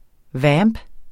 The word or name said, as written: VAMPENE